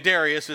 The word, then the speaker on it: Darius: Now, I've always learned this guy's name is Darius, not however he pronounced it, but Darius is how we're going to pronounce him.